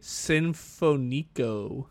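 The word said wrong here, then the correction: Sinfónico: You put the accent on the wrong. There's literally an accent above that O. – Whoops. – That's okay. – Well, then you say it. – Nietzsche Sinfonico.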